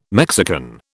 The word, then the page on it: Mexican: British English